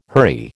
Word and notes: hurry: American English

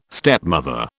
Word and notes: stepmother: British English

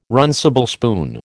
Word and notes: runcible spoon: American English

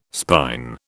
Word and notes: spine: British English

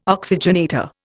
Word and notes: oxygenator: Indian

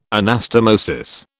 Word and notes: anastomosis: British English

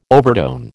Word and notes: overtone: American English

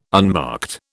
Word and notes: unmarked: British English

